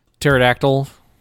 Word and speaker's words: pterodactyl: Words containing a silent P. – Pterodactyl.